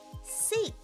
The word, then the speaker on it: seek: Seek. Seek. アルファベットの C の文字と同じ発音です。